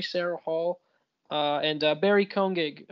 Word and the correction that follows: Keoghan: Is that his name? – Yeah. – Did I say it right? – No, I was laughing at the Sarah Hall thing. – Barry Cohen. Koenig? – Keoghan.